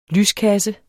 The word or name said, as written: lyskasse